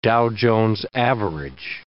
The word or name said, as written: Dow Jones average